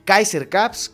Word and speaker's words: Kaiser Caps: Ya lo saben, Facebook e Instagram, ahí aparecen Kaiser Caps, Kaiser este, con K eh, acento en la A y caps, como gorras en inglés, ¿no?